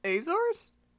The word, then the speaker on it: Azores: Azores.